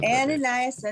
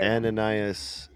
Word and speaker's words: Ananias: This is Ananias and Sapphire. I'm gonna pronounce Ananias and Sapphire. – Ananias and Sapphire. Yes, there we go.